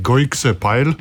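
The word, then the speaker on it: Gooikse Pijl: Se poskušam zdaj pravilno izgovoriti dirko v Belgiji, Goikse Pajl. Ja?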